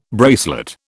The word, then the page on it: bracelet: British English